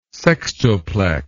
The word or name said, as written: sextuplex